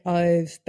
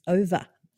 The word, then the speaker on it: Ove: I have just started A Man Called, and I was going to say Ove, but I've heard that that is not how it's pronounced. It's Over.